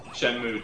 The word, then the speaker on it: Shenmue: Shenmue 2.